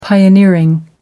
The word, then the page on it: pioneering: American English